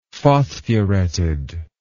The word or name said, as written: phosphureted